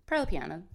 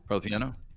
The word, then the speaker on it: Parlapiano: You say Parlapiano, right, or do you Parlapiano? – Pearl Piano. – Pearl Piano. – That's right. the the I. It's like the proper pronunciation. Most people say Pearl Piano.